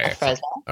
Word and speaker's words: Afrezza: I want to leap ahead a little bit for you because Do you use Afrezza now? – I do. – Am I saying it correctly? – You are. – Okay. – Afrezza.